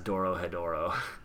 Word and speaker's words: Dorohedoro: Dorohedro, I think it's called. – Okay, Dorohedro. Dorohedro, yeah. Whatever. – Yeah. – Doro, you'll be able to spell it properly if I say it as Dorohedoro.